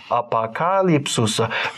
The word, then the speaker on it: апокалипсис: Там еще и ударение. – Апокалипсис. – По-русски оно апокалипсис. – Апокалипсис. – Как лепс.